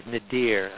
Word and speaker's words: nadir: I want to explain that word I use, nadir, spelled, in case I'm not pronouncing it clearly, N-A-D-I-R.